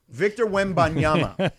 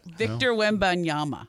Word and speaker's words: Victor Wembanyama: Victor Wembanyama. – Victor Wembanyama.